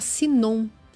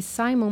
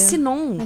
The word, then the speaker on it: Sinon: Na verdade, a operação chama Sinon. Tá escrito aqui Simon, mas é... – Ah, é Sinon. – É Sinon. Então acho que deve ser a pronúncia Sinon também.